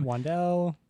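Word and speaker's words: Wan'Dale: The Giants, Wandale Robinson went to the Giants, and that's how you say his name. – Wandale.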